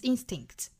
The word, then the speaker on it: instinct: To 若读成 t, trust one's instinct, 这个 one 后面加上了 s 要读成 z, one's instinct 再和后面的 in 连读 one's instinct。那我们注意 instinct 这个单词呢要重读在第一个音节是 instinct,